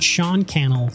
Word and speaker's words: Sean Cannell: I'm your host, Jared Lohman, Vice President of Customer Experience, and today I'm joined by my friend Sean Cannell, YouTuber, international speaker, coach, and creator of Think Media. Sean, did I say your last name right? – You did. Sean Cannell rhymes with YouTube channel.